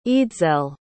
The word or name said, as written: eadsele